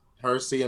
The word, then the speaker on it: Hersey: Hersey and Mercy rhyme with Percy.